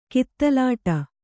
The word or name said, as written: ಕಿತ್ತಲಾಟ